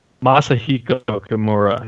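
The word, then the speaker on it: Masahiko Kimura: This is why we call it a Komura, is because of Masahiro. I can't say his name. I'm messing it up. Masahiko or Hiro Komura. That's why this match is that important, that we have a move named after this guy. – Masahiko Komura. Yep.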